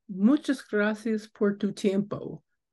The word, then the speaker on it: Muchas gracias por tu tiempo: Muchas gracias por tu tiempo.